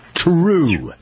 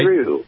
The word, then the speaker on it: Troo: This product line is called True, but spelled T-R-O-O. So is that the right way to pronounce it? – That is correct, True. All right. – True.